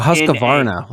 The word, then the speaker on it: Husqvarna: Oh, Husqvarna.